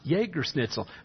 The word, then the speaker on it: Jägerschnitzel: But it starts with a J. How do they pronounce their J as a Y? Well, it's just the German language. They substitute J's for Y's all the time. It's spelled Jägerschnitzel, but pronounced Jägerschnitzel. That's how we came up with Jehovah.